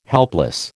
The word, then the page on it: helpless: American English